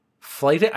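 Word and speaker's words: flyting: Flighting? I know the concept of flight, but what is flighting? This is spelled F-L-Y-T, flighting.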